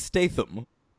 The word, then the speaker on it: Statham: and that is Jason Statham. – Ooh, why are you pronouncing it that way? – I don't know how to say it. – I'm pretty sure it's Statham.